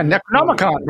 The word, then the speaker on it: Necronomicon: This needs some pronunciation. It's Necronomicon. – The Necronomicon. – Yeah, Necronomicon, bro.